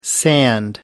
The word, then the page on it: sand: American English